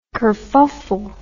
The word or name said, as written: kurfuffle